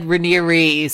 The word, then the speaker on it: Raniere: Well, one of mine is a guy who doesn't deserve to have his name pronounced correctly, who I'm going to continue to call Keith Raniere. But apparently it's actually said Raniere.